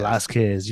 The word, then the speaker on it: Velasquez: Like um, how how do I pronounce it? Again? Cain Velasquez. – Velasquez. You Velasquez. Said right, yeah.